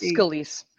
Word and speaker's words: Scalise: Is it Scalise or Scalise? – Scalise.